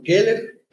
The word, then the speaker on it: Keller: Pues fíjate que mi nombre es Fillmore Keller Pérez Montiver, ese es mi nombre completo. Ah. Entonces, el Kel es de mi segundo nombre, se escribe tal cual K-E-L-L-E-R, como Helen Keller.